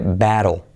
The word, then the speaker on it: battle: ¿Cómo se pronuncia batalla? Fijaos cómo se dice. Se dice battle.